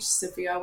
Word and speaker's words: Sophia: So my name is spelt with a PH, Sophia with a PH.